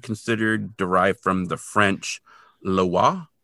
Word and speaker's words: Loa: And anyone who has watched Kolchak the Night Stalker, the zombie from 1974, the second episode, might remember it because he actually, of course, mispronounces it as Lois, and he calls her like Mama Lois instead of Mama Loa.